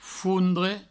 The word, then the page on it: fondre: foundre; Provençal